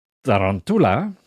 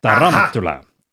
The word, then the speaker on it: tarantula: De klemtoon in het woord tarantula ligt op de tweede lettergreep. Dus op de letters an. Tarantula.